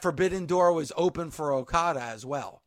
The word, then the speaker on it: Okada: Another favorite of mine, personally, the Rainmaker, making sure I'm getting his name right, Kazuchika Okada. – Yep, Okada at number two, the Rainmaker himself.